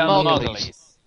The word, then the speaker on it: Margulies: How do you say it, from The Good Wife? – Julia Margulies. – Thank you. It's just the Margulies that threw me.